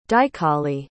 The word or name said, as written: dichali